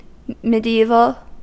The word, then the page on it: mediaeval: American English; /mɪd.ˈi.vəl/